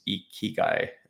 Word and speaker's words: Ikigai: For people who are wondering how to pronounce this is Ikigai. This is how you pronounce it here.